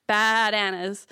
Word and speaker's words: Bad Anna's: Yes, it's three A's like a bleeding sheep. Bah, Bad Anna's.